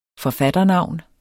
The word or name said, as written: forfatternavn